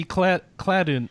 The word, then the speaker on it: Cladun: I think it's called pronounced Cladun or something. – Oh, Cladun. Yeah, classic dungeon. – I think it means classic dungeon, so I wasn't really sure how to pronounce it. – No, you're right. You're right. It must be Cladun.